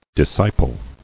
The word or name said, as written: Discipling